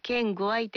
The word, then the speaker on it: 県外: これは県具合と発音、表記されたのは、どういったことからなんでしょうか。 – これはこの本の中で、ちょっと嫌なやつが、お前は県外なんだよっていう、言うときに、県具合ってちょっとカッコつけて言うっていうのが、なんか、自信はないんですけども、その西の方の発音が県具合らしいんですよね。